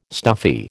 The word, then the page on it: stuffy: American English